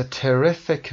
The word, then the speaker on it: terrific: Revenge به معنی انتقام is a terrific motivating force. تلفظ terrific رو دقت داشته باشید. Terrific در قسمت R ما استرس رو میذاریم.